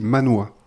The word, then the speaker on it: manhwa: D'accord, on dit manhwa. – Alors, je ne sais pas si c'est comme ça que ça se dit, mais en tout cas, M-A-N-W-H-A. D'accord. Je prononce Manois.